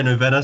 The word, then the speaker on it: Novena: Is it Novena? – That's how I'm saying it. – Yeah, we say Novena.